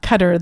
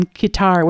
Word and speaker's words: Qatar: But I was able to find an article that actually says that the Arabic for us translates much closer to our word cutter than um, than kitar, which is how many people pronounce it in the media.